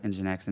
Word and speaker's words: Nginx: But it's spelled Nginix and pronounced Nginx, and it's kind of blown away Pound so far, but we just haven't switched yet because we're still working on it.